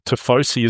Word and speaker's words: tifosi: the Tifosi. Is that the correct pronunciation? – Yep, you got that spot on.